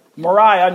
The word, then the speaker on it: Mariah: Mariah, not Maria, that's how she pronounced it.